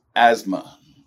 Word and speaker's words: asthma: The only word in the English language with the silent T is asthma.